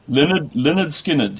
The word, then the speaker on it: Lynyrd Skynyrd: Simple Man is your next choice, and the pronunciation of the artist, is it Leonard Skinner or Lynyrd Skynyrd? – Lynyrd Skynyrd, Here it comes.